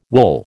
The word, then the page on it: wool: American English